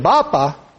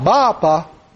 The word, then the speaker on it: Bapa: Now, he pronounced it a little differently than I did. Bapa, it was like almost like a two-syllable thing, or an accent on the ah, Bapa.